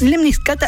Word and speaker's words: lemniscata: Y si yo te digo lemniscata, perdón, vamos de nuevo porque lo dije mal. Lemniscata.